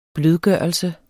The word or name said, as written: blødgørelse